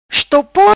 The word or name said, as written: Estopor